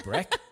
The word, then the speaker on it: Brack: and it's pronounced Brack as she says, if you tread on a duck, the sound that makes, Brack, that's the way she says it.